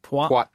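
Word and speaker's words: Poite: Poit.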